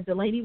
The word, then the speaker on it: Delaney: And on my Facebook, you can look me up under Delaney Williams, and that's D-E-L-A-N-E-Y, Williams, spelled like normal.